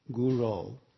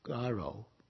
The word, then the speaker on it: guru: And in one talk Srila Prabhupada gave fairly early in the movement, he commented that the devotees were mispronouncing the word guru in Bande Guru Sri Charanaravinda and saying something like garo, which means cow.